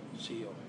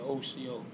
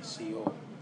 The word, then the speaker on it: Sio: Some people try to tell you OCO, but it's not the way we slang Indians speak. We say CO.